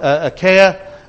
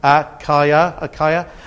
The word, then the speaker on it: Achaia: At the bottom, you've got Achaia. That's how you pronounce it. See the little reminder at the bottom? Achaia.